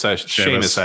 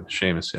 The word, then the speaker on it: Seamus: It's Seamus, actually. Seamus, yeah.